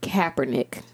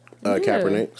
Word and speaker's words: Kaepernick: And but even if, why would he have to wait for, Kaepernick or whatever the Kaepernick. – Kaepernick. – Yeah. Got it. It's spelled weird, but it's easy to say.